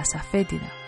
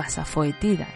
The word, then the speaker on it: asafétida: El polvo de ajo suele ser muy utilizado, pero también te sirve cualquier planta que sea maloliente, como por ejemplo la asafétida o asafoetida, que también se pronuncia así.